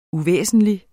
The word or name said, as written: uvæsentlig